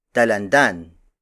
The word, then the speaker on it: dalandan: Another native color is Talan Dalandan.